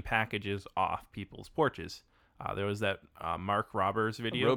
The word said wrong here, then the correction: Rober: Rober. I think it's pronounced Rober.